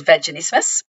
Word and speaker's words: vaginismus: I'm wondering too, and not to jump the gun here because you might have this slated to chat about later, and I'm going to mispronounce this, but is this where that condition, is it vaginismus? – Vaginismus. – Yes, thank you. I always pronounce that wrong.